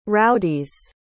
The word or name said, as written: rowdies